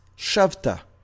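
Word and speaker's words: shavta (שבת): In this particular verse, it's spelled shin bet tav, pronounced shavta. You will return, shavta.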